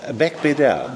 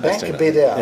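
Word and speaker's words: Beigbeder: anderem auch einer, von dem ich immer nicht weiß, wie, wie spricht man den aus? Ganz berühmter Autor. Frederik. – Äh, Frederik äh, Beck-Beder. – Beck-Beder. Ja.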